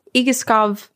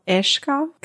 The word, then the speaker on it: Egeskov: So, uh, I am going to begin us with uh, telling you about a place called Igiskov Castle, which is the way that I butcher it as an English speaker. But I believe after listening to about 100 videos of Danish people pronouncing it, it's called Ishkov Castle.